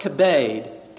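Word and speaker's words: kibbed (כבד): The Hebrew word translated honor, as for example in the Ten Commandments, thou shalt honor thy father and thy mother, in Hebrew is pronounced kibbed, K-I-B-B-E-D, kibbed.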